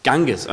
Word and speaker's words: Ganges: 2013 versammelten sich etwa 120 Millionen Menschen, um sich im Fluss Ganges, spricht man es so aus? Ganges? Ganges, okay.